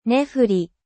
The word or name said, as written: nefle